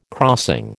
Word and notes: crossing: American English

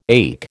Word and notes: ache: American English

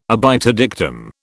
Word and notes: obiter dictum: British English

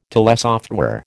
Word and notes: telesoftware: American English

